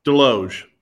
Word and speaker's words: Deloge: Deloge. Deloge. – Deloge. Okay. Okay. There you go. I was pronouncing the S, but that so that sounds good.